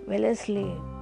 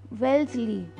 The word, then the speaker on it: Wellesley: It is Wellesley. One second. It's Wellesley. Okay. This should be pronounced like that.